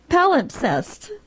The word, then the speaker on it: palimpsest: Palimpsest, is that right? – That's it.